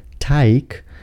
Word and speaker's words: Teig: Nie czytamy go jako Teich, ponieważ to słowo piszemy przez CH i oznacza staw. Teig piszemy przez G i tak też je wymawiamy.